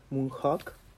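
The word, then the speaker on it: Moon Hoax: La conspiración en Gringolandia se llama Moonhook, si es que lo estoy pronunciando bien, Moonhawk.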